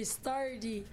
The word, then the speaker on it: Stardy: C'est Stardy. – Stardy. – Alors, c'est S-T-A-R-D-Y, mais prononcé Stardy.